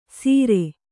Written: ಸೀರೆ

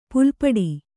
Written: ಪುಲಿಪಟು

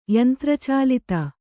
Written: ಯಂತ್ರಚಾಲಿತ

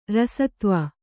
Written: ರಸತ್ವ

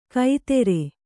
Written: ಕೈತೆರೆ

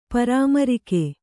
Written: ಪರಾಮರಿಕೆ

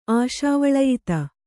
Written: ಆಶಾವಳಯಿತ

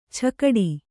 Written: ಛಕಡಿ

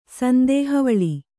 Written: ಸಂದೇಹವಳಿ

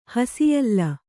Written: ಹಸಿಯಲ್ಲ

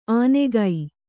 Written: ಆನೆಗೈ

